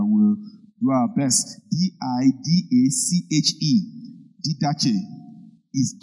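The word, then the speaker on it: Didache (διδαχή): Greek word didache. Rabbis. How do you pronounce it? Didache. Didache. D-I-D-A-C-H-E. D-I-D-A-C-H-E.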